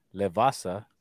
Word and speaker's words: Le Vasa: But with our brother that came up with Levasa, right? Am I saying that right? – Yes, sir.